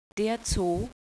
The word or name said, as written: zoo